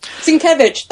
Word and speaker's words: Sienkiewicz: Sienkiewicz, thank you.